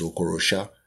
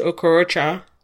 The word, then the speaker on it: Okorosha: I didn't know that's how you pronounce that. I always mentally pronounce it as Okorocha.